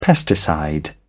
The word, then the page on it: pesticide: British English